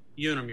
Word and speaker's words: Unum: How do you say it? – Unum, Your Honor.